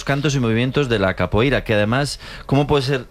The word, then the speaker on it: capoeira: Pues todos sabemos lo que es la copeira, ¿verdad? – Capoeira.